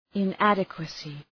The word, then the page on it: inadequacy: ı’nædıkwəsı